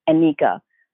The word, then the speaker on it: Nneka: So somewhere along the way, the Ugandan, I believe, pronunciation came in and I'm Nneka.